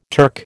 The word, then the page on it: Turk: American English